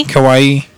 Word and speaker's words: kawaii: I think the pronunciation is kawaii. – Oh, kawaii? – Kawaii. Yeah, like Hawaii. – Hawaii, but with a K.